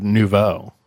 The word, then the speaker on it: nouveau: He's described his acting style as nuevo shamanic. – Nuevo? – Or shamanic. Am I saying that? Maybe I'm not saying it right. It's looks like nuevo to me. – That is the word nouveau.